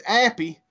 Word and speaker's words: Appy: That's Appy for crappy. Also rhymes with sappy. Also rhymes for not happy.